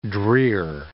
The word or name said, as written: drear